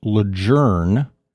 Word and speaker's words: Lejeune: And I actually found out it can be pronounced Lejeune. – Never heard that. – Yeah, because that was how the family pronounced the name of the gentleman that the base was named after. So if you run into some old timers and they pronounce it Camp Lejeune, well, that's why. See, edutainment.